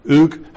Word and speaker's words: Uig: Since the 1820s, when Lewis was totally transformed in that mighty movement, this is a prior revival, originating in, I think it's pronounced Uig, U-I-G, Uig, under Alexander MacLeod, all over Lewis, these principles of prayer and of waiting upon God for his blessings are well established.